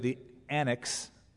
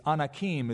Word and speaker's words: Anakim: Anytime you see an I-M at the end of a word in the Old Testament, it's pronounced im, and that is the male plural in Hebrew. You don't say the annex. It's Anakim is the male plural, the im ending.